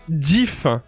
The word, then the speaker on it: GIF: Alors, euh, ça se prononce GIF, donc il y a un, semblant de D devant, donc GIF, voilà.